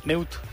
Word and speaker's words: Meute: Y nos pide que si podemos por ahí ponerle después la canción Sail de ellos, de Meute, Mute, no sé cómo se pronuncia, perdóname Lalo. – Meute. – Meute. – Es alemán creo, ajá.